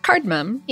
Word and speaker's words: cardamom: Cardamom? Is that how you pronounce it? – Cardamom, yeah. – Oh, yeah, you're right. I was putting in an extra cardamom. – Well, like there's a cardamom. There's three distinct syllables in there.